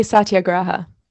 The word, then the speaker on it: satyagraha: I'd say satyagraha.